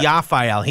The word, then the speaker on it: Teofil: So like it rhymes with Raphael? – Yes, okay. Teofil.